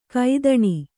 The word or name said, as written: ಕೊತ್ತಣ